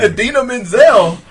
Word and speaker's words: Idina Menzel: And Idina Menzel.